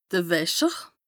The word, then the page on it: de Wäscher: Bas-Rhin; Strasbourg